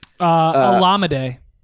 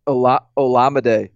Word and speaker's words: Olamide: Olamide Zaccheaus, is that how you say his name? – Olamide. – Olamide. – Yeah.